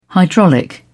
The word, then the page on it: hydraulic: British English